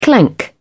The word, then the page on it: clank: British English